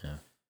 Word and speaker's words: Ye: I did not find out until recently that you pronounce it Ye.